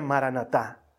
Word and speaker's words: maranatá: Esta palabra se pronuncia maranatá.